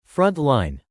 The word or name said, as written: front-line